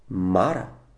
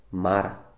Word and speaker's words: Мара: Българите казват Мара, но правилно е ударението да пада на втората сричка. Мара.